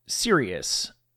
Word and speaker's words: Sirius: You may or may not be familiar with a piece of music that's called Sirius, spelled the same as the constellation and the satellite radio company I used to work for, not like the opposite of joking.